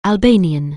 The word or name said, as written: Albanian